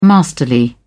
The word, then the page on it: masterly: British English